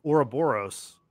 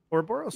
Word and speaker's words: Ouroboros: We're here today to talk about episode one titled Ouroboros, right? Ouroboros? – Mm-hmm.